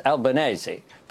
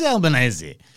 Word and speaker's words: Albanese: Christopher Pyne actually got it right in terms of the Italian pronunciation. It's Albanese. But you don't say spaghetti bolognese, do you? So, you know. – So it is Albanese, except it's got to be pronounced that way.